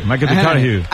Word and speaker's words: McConaughey: Mateo McConaughey. – Sí. – Que hay que ir corrigiendo la pronunciación. – McConaughey.